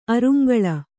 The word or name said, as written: ಅರುಂಗಳ